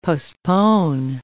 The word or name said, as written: postpone